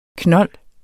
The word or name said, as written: knold